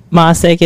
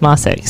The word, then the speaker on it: Mocek: My last name is spelled Mocek, unlike how it's pronounced. And, Eliza Jane Mocek is my Instagram handle. And you can find me on Facebook, too, Elizabeth Mocek.